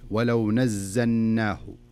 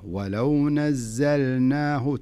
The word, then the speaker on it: ولو نزلناه: فلذلك لا تقول ولو نزلناه ولو نزلناه, لا خلها اللام ظاهرة ولو نزلناه, تنطق اللام ثم تنطق النون معي.